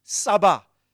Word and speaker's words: tsaba: Hosts in the Hebrew is Saba. T, the T is silent. T-S-A-B-A. Saba.